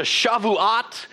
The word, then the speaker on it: Shavuot: And it's pronounced in the Hebrew, the Shavuot.